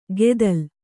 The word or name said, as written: ಗೆದಲ್